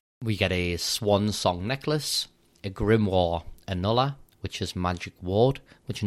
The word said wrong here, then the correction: annulé: It's pronounced annulé. – Annulé, sorry.